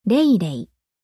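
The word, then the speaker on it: 蕾蕾: メス、カタカナ名はレイレイ。漢字は、つぼみという字を二つ並べて、レイレイと読みます。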